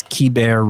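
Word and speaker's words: Keibert: It's Kiber Ruiz. Not Kiebert or Kybert or Kabert, as we were calling him.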